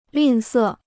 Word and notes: 吝啬: lìn sè

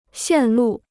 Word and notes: 线路: xiàn lù

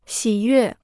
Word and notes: 喜悦: xǐ yuè

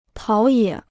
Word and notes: 陶冶: táo yě

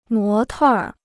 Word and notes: 模特儿: mó tèr

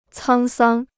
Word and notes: 沧桑: cāng sāng

